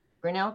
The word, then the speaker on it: Grinnell: And then how'd you end up at, am I saying that right? Grinnell College? – Grinnell College in Iowa.